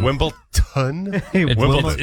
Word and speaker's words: Wimbledon: D-O-N. Wimbledon? – It's D-O-N. – Like Downton.